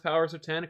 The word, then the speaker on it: Powers of X: One, number one, Powers of X is pronounced Powers of Ten according to Hickman.